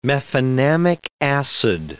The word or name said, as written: mefenamic acid